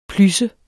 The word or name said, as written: plys